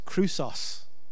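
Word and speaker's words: chrysos (χρυσός): But it's amazing, the Greek word for chrysalis is a word pronounced chrysos.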